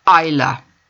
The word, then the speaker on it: Isla: Isla can also be pronounced without the S, Isla.